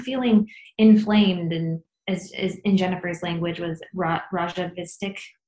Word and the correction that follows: rajasic: How do you say it? – Rajasic. – Rajasic. There we go.